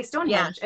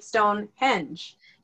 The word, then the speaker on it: Stonehenge: Okay, so we're from America, and we say Stonehenge, and they say Stonehenge. – Yeah, they say it different. – Their accent is on the second syllable, where ours is on the first, and it's with every word they say, so it's like Stonehenge.